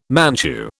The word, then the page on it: Manchu: British English